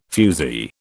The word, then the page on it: fusee: British English